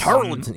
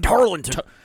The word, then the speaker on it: Tarleton: Tarleton. You got to say it like Tarleton. – Tarleton. – Like Charlatan.